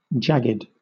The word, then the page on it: jagged: British English